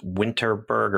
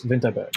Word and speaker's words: Vinterberg: Once again, I will lean hard into my Mads Mikkelsen fandom and try to pronounce it with a Danish accent and say this is Thomas Vinterberg. Oh, that's much better than my pronunciation. – And as a filthy American, Thomas Vinterberg is correct.